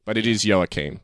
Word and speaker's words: Joachim: So they mispronounce it as Joachim, sort of like Joaquin Phoenix. – Uh-huh. – But he gets upset and he's like, no, it's Joachim.